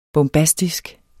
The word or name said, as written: bombastisk